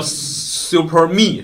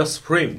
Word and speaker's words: Supreme: 包括它和这个有有有人叫 Super Me 是吧？Supreme 哈，人本正常发音叫 Supreme，就是正常它和 Supreme 合作的一些款式，也可能只能在纽约的这个店里才有。因为我去德州，比如说达拉斯啊、休斯顿的这些耐克店，或者是阿迪达斯店，你就看不到他们合作的这些款了。